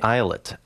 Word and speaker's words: Islet: Part of a soundtrack to a novel, and that actually is from the Islet album, spelled like Violet without the initial V, and it was released as a companion to Neal Stephenson's recent book, Anathem.